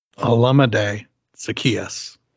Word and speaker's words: Olamide Zaccheaus: Olamide Zaccheaus.